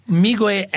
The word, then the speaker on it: Meegwai: Miigwe, M-E-E-G-W-A-I, is a completely made-up word.